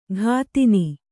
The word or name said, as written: ಘಾತಿನಿ